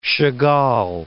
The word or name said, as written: Chagall